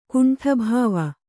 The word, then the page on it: ಕುಂಠಭಾವ: kannada